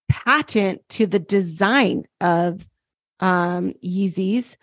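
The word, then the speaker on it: Yeezy: And his biggest moneymaker, the sponsors of his Yeezys, that's how you say it, right? – Yeah.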